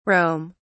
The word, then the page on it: rome: British English